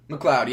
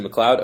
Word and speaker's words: McLeod: Rodney McLeod? – McLeod, yeah. – Is it McLeod? Okay.